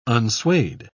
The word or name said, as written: unswayed